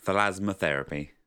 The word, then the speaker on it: Thalasmotherapy: Okay, so that was weekly song this week, called, and I hope I'm correctly pronouncing this, Thalasmotherapy? – Yeah, Thalasmotherapy.